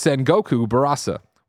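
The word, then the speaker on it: Sengoku Basara: Sal Romanu from Gamatsu said, "Greg, you said Sengoku Barasa and my ears bled. It's Sengoku Barasa." Well, Sal, if you spell it the exact same way, I can't change it for you. – You keep swapping the R and the S.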